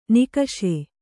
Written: ನಿಕಷೆ

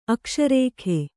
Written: ಅಕಶೇರುಕ

